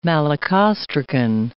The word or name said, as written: malacostracan